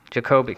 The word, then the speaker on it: Jacoby: On television, Herschel Bernardi starred in his own series, Arnie, and played Lieutenant Jacoby on Peter Gunn. – Jacoby. Thank you. – Okay, long O.